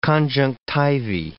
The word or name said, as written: conjunctivae